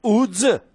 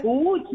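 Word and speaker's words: Łódź: Κανονικά είναι λάμδα με γραμμή στη μέση, το οποίο προφέρεται U. Άρα η ομάδα λέγεται UDS. Όσο παράξενο και άσο ακούγεται. – UDS. Μισό Ούτς, λεπτό. Γιατί, η πόλη είναι Ούτζ.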